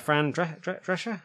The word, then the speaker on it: Drescher: Yeah, Fran Drescher? Am I saying that right? – Yes.